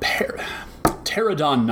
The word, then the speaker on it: Pterodon: Next up, we've got Pterodon Knight. The P is silent, people.